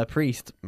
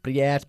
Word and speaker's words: priest: Todo el mundo dice priest, priest, priest. Es priest. – Priest. – Priest, ¿ok? Como prisa. Priest.